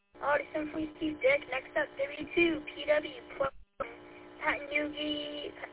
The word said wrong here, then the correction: Patchogue: Am I saying that right? Pat Newgie. – If you take your finger and you hold it over the UE and forget the UE, it just says Patchogue.